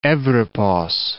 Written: Evripos

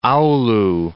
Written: Oulu

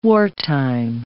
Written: wartime

